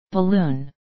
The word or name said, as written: balloon